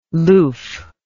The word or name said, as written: loof